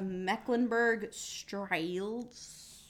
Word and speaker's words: Mecklenburg-Strelitz: So he married and met on the same day Princess Charlotte of Mecklenburg-Strelitz.